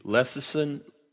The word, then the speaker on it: lecithin: If the myelin sheath is the lecithin, how do you say it? – Lecithin.